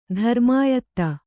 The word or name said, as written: ಧರ್ಮಾಯತ್ತ